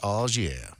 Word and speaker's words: au jus: Do you know that au jus sauce is actually pronounced au jus? – Au jus.